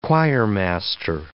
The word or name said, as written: choirmaster